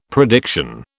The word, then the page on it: prediction: British English